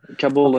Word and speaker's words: Kabul: people have asked for the people in Kabul, is that what's how you pronounce it? – Yeah, Kabul and Okay.